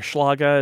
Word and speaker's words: Schlage: Schlage or Schlage is how we would say it in German.